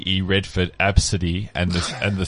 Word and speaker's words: Abcde: This is the kid's name, but it's pronounced A B City. – Absidy. – Absidy.